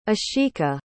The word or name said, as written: ashika